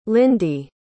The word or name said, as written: lindy